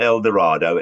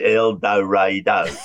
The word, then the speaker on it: El Dorado: It's not El Dorado, it's El Dorado.